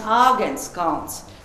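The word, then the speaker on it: Āgenskalns: Und die Letten können nicht die Mannlaut H aussprechen. Sie sagen Agenskans. Kans ist auf Lettisch Berg.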